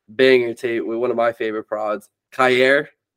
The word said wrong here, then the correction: Kyrie: That's how you say his name? Kyer? – It's Kyrie.